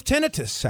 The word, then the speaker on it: tinnitus: Yeah, tinnitus. – Yeah, that's the word. – That's not how you say it. – T-I-N-N-I-T-U-S. Tinnitus. – No, tinnitus.